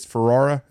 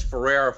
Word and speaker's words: Ferrara: Am I saying it correctly? It's Ferrara. Is that right? – Yes, Ferrara Fire Apparatus.